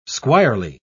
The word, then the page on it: squirely: British English